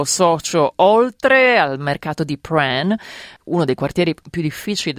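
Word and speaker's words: Prahran: Perché chi ascolta, che magari ha letto come si scrive sul podcast, dirà ma come Pran? E invece è proprio così che si dice.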